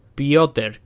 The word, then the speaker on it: Piotr: El primero de ellos, del contra Ticio Torres, Gilbert Vance contra Hansa Chimaev, Aljamain Sterling contra Peter. No, Peter no, Piotr, creo que se pronuncia.